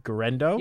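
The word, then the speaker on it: Guerendo: And I know I brought this guy up before, Isaac, I'm going to pronounce his Garendo. – Yep, I think you have it right.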